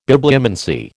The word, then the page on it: Bibliomancy: American English